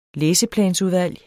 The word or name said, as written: læseplansudvalg